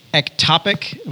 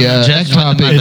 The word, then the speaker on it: ectopic: So in your sermon on the application in regards to abortion, you did not address ectopic, am I saying it correctly? – Yeah.